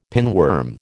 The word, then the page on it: pinworm: American English